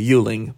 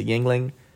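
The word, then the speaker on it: Yuengling: I think it's called Yingling. I called it a Yuling, but he said it's a Yingling.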